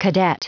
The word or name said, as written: cadet